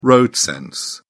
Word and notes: road-sense: British English

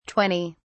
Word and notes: 20: British English

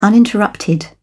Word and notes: uninterrupted: British English

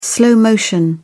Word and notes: slow motion: British English

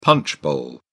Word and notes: punch bowl: British English

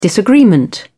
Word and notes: disagreement: British English

